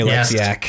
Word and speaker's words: Oleksiak: Is it Oleksiak? – Yes, Jamie Oleksiak. Yes.